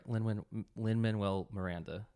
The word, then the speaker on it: Lin-Manuel Miranda: Lin-Manuel Miranda.